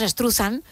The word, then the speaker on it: Struzan: Bueno, pues Drew Struzan, o Struzan, o Struzan, o como cada uno lo quiera pronunciar, nosotros decimos Struzan.